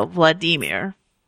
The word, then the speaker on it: Vladimir: But I have heard that instead of like Vladimir, how we Americans think it's pronounced, I was told that at least this gentleman's first name rhymes with the word redeemer. So Vladimir Nabokov.